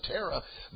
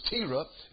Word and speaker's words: Terah: I looked up that name, and it's actually pronounced, I pronounced it Terah, but the way they pronounced it was Terah.